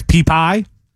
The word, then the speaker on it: Pepi: How else would you pronounce P-E-P-I? – We've been through this before. We've done this before where you're like, how else would you pronounce Isaac? – It's Pepi. – And it's like, well, maybe Esau. – If it's Pepi, then he pronounces his name wrong.